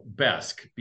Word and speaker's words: Besk: Besk, B-E-S-K. I'm not saying it with the proper Swedish pronunciation. It's the American way to say it.